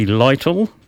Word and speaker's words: Lytle: Right, anyway, that track that I played there was from the man called Johnny Lytle, and it's spelled L-Y-T-L-E, pronounced Lytle, I'm told,